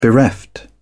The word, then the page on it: bereft: British English